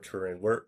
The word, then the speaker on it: Turin: one of the is the Shroud of Turin. – Right. – Am I pronouncing that right? – Yeah, the Shroud of Turin.